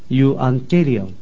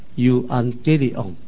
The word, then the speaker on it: Evangelion: 对，所以这个福音这个字眼，希腊文的发音叫做 Evangelion。 – 所以福音喺希腊文嘅发音系 Evangelion。 – Evangelion。 – 啊，这是希腊文的发音。